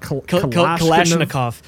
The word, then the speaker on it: Kalashnikov: Kalashnikov? – The AK-47 assault rifle company changed the world. Now there's an AK-47 kamikaze drone. So how do you pronounce that? – Kalashnikov.